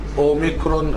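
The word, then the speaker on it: أوميكرون (όμικρον): نعم، دي لا لا لا، هي دايره كده، دايره دايرة كبيرة زي حرف الأو بالظبط، فهي دي بتتنطق أوميكرون أو أوميكرون زي ما هي مكتوبة كده بالإنجليزي. بتتنطق ضمة خفيفة قصيرة، وشفايفك مفتوحة مش بضم الشفايف، تتنطق أو أو.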